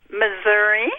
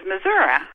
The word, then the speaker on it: Missouri: Missouri. – Ah! – I am originally from Wisconsin, and that's why I say Missouri, but everybody in Missouri says Missouri.